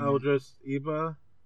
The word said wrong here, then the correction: Idris Elba: Elba. I feel like an asshole because I can't pronounce his name. – Idris Elba. – Yeah. – Idris Elba, there you go.